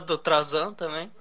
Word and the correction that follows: Tarzan: não, o Tarzan.